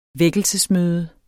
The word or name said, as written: vækkelsesmøde